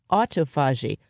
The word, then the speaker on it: autophagy: It's auto, A-U-T-O, and then phagy is P-H-A-G-Y, autophagy.